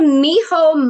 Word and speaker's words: Miho: Well, let's go to Miho Michael. Right? I hope I said that M-I-H-O? – Right. – Oh, you got him. Yeah. Oh, you got it.